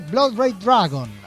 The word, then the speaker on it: Blood Red Dragon: Blood Red Dragon, así habría que pronunciarlo.